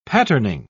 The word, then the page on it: patterning: British English